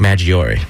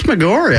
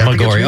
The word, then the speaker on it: Maggiore: and then at its southern end is Lake Maggiore. Did I pronounce that right? – I think it's Maggiore. – Maggiore, okay.